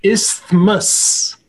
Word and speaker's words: isthmus: I just realized I said it wrong. It's isthmus.